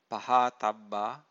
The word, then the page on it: pahathabba: Pali